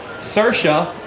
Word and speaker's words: Saoirse: And I had to write down a pronunciation key for her. Saoirse Ronan.